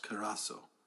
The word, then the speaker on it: kerusso: That word preach is a word in the Greek called kerasso. Kerasso. K-E-R-U-S-S-O. It's pronounced kerasso.